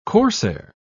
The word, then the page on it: corsair: British English